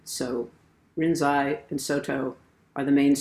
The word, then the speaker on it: Rinzai: Now, the Japanese pronunciation of Linji's name is Rinzai.